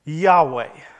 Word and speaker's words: Yahweh: So later in time, when the Hebrews started placing vowels into the words, they came up with a word that was pronounced Yahweh.